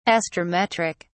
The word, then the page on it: astrometric: British English